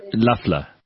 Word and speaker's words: Loeffler: Apparently this Loeffler, how do you pronounce her name? – Loeffler. Kelly Loeffler.